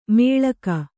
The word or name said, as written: ಮೇಳಕ